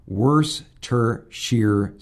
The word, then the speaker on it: Worcestershire: From Rich in Hawaii, it's pronounced Worcestershire sauce. Worcestershire. Worcestershire. So we learned something today.